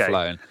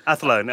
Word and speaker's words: Athlone: Athlone. – Athlone.